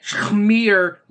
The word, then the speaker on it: schmear: Saying it Shmir wasn't a flub on me. That's how it's spelt. Instead of Shmir campaign, Shmir. – S-C-H-M-E-A-R.